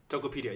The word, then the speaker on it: Tokopedia: You went to the Valley, you worked at Facebook, you worked at Athos, and then you went back to Jakarta, and how do you pronounce this? Tokopedia? – Tokopedia, yeah.